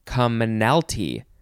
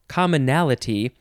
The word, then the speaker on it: commonalty: It's commonalty. That's not how you say it, the word. You say commonalty. It's a weird, weird word. It's not commonality. It's commonalty.